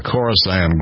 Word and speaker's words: Khorasan: K-H-O-R-A-S-A-N, Khorasan.